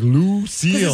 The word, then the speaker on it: Lou Seal: I was like, why do you pronounce Lucille weird? You're like, Lou Seal.